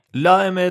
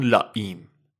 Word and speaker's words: La Eme: به اسم لائمه L A E M E معنیش میشه حرف ام. ام به اسپانیولی میشه M لائمه تلفظ اسپانیولیشه یا بهش میگن لایم